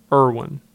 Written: Irwin

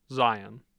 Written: zion

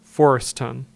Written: Forreston

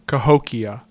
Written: Cahokia

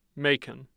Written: Macon

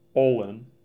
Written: Ullin

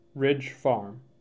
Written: Ridge Farm

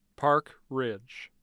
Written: park ridge

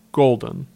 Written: Golden

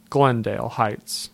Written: Glendale Heights

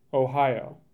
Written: Ohio

